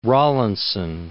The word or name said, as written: Rawlinson